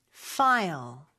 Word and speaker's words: file: 파일이 아니라 영어로는 F로 발음해 주셔야 돼요. File. File. 이렇게요.